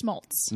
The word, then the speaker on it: Smoltz: Anyway, it just happened to be, I always say his name wrong, John Schmoltz. – Smoltz. No schmr.